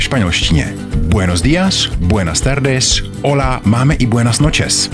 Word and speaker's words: hola: Hola se píše z H, ale pozor, Španělé H nikdy nevyslovují.